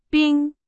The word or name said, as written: 冰